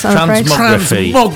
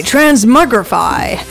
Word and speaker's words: Transmogrify: Transmogrify. – Oh, okay, Transmogrify.